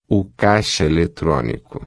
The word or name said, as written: caixa-eletronico